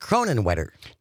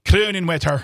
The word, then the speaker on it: Kronenwetter: By the way, just to clarify, Julianne texted in, listening on WXCO, it's pronounced Kronenwetter. – Kronenwetter.